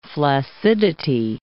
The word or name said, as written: flaccidity